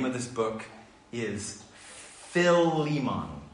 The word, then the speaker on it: Philemon: Philemon.